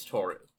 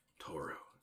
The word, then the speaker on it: Toru: I believe her name is Toru. – Toru. Okay. That's two syllables, but I was close.